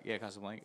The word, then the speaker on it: Casablanca: I've never fully seen the whole movie of Casablanca. Casablanca. Is that how you say it? – Yep.